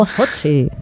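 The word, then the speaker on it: Ötzi: Otzi, creo que se llamaba, un nombre muy, muy noruego. – Utzi, no sé, lo pronuncias tú mejor que yo. – Utzi, vale.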